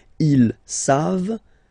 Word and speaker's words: ils savent: You don't pronounce it, so you get the sound save. V-v-v-v-v, save. Okay? Ils savent.